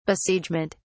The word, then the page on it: besiegement: British English